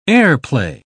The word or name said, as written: airplay